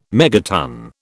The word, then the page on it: megaton: British English